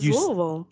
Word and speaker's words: Louisville: That's Louisville. – If you want to know how to say it right, just fill your mouth with marbles and then try to say Louisville.